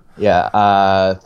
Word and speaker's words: Nyong'o: Yeah, Lupita Nyong'o.